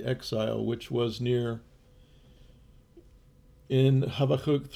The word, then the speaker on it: Habakkuk: Shirley and I, in our morning readings the other day, were in the book of Habakkuk is the Hebrew pronunciation, I believe. I'm not totally perfect on my pronunciation, but Habakkuk is considered one of the minor prophets, and I'm going to read you a little note from F.B.